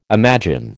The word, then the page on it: imagine: American English